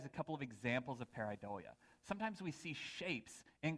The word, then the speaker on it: pareidolia: In psychology, there's a condition, and I believe I'm pronouncing it correctly. It's called pareidolia.